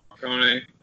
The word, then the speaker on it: Morricone: Morricone? – Morricone? Yeah, Morricone.